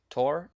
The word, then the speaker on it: Thor: Yeah, I think Baldur is the brother of Thor and the son of Odin. – That's correct. – Okay. – Also, I think it's pronounced Tor.